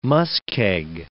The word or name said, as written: muskeg